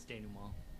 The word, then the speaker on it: Denouement: How does he pronounce it? – It's Denouement. – It's Denouement. Denouement, yeah, French.